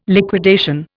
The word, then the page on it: liquidation: Indian